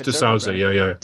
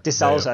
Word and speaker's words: D'Souza: Dinesh D'Souza. – D'Souza, that's what you pronounce it.